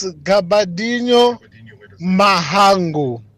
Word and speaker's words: Gabadinho Mhango: Rob, it's Gabadinho Mhango. Please make sure that next time when you pronounce it, Gabadinho Mhango.